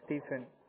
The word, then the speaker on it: Stephen: Last name is Brezina, B-R-E-Z-I-N-A. And I'm sorry, what was your name? – My name is Stephen. – How do you spell that, sir? – S-T-E-P-H-E-N.